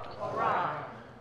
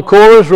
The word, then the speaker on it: Korah: Korah. – So in Hebrew it's Korah. We usually pronounce it Korah.